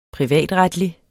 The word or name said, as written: privatretlig